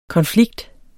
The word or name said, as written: konflikt